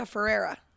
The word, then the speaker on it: Ferrera: And America Ferrara is leaving. – America Ferrera. – All right. – That's how you say her name.